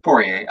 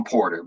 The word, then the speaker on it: Poirier: Poirier. – I always want to call him Porter, but anyways.